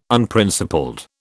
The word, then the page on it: Unprincipled: British English